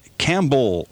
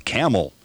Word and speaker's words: Campbell: it's not Campbell, Ohio, it's Camel. Like the ship of the desert, Camel.